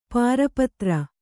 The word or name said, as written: ಪಾರಪತ್ರ